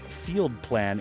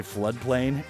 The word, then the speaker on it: FLDPLN: This is a new single from the Dream Electronic artist Field Plan, and it's spelled FLDPLN, so it gets a little confusing. – Hmm. – I was going to say Floodplain if it didn't get pointed out, right.